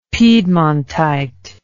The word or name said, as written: piedmontite